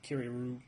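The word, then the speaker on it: Curieux: All right, so we got the Curio. Which I'm not sure I'm pronouncing this correctly. It's C-U-R-I-E-U-X. – Curio.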